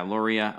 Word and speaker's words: Loria: Is it Loria or Loria? How do they pronounce? Yeah, Loria.